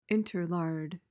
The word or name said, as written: interlard